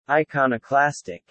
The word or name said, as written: iconoclastic